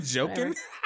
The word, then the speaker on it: Joaquin: You said Joaquin? Joaquin playing the Joker? Yes, that's exactly who he is.